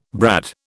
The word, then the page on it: brad: British English